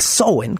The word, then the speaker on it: Samhain: Y por oposición, el Samhain, o el, bueno, el Samhain, que es la pronunciación correcta,